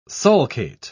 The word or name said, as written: sulcate